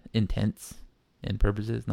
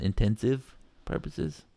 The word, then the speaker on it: intents and purposes: And for all intents and purposes, by the way, that's how it's said, intents and purposes, not intensive purposes.